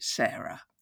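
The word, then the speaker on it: Sara: You can contact Sarah at Sarah, that's S-A-R-A, although we pronounce it Sarah, at the TG, with a hyphen between the "the" and the TG, .com.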